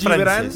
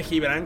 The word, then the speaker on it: Gibran: Realmente es Gibran. Se escribe Gibran, claro. Con G, pero es Gibran y es libanés.